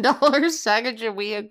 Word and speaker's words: Sacagawea: Did I say that right? Sacagawea? – Sacagawea. Yeah. – Oh, I said it right.